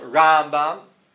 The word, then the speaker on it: Rambam: Rambam, the stress is on the first syllable. Rambam, mil el, and then the Ramban, mil ra, it's on the second syllable.